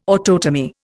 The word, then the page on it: autotomy: Indian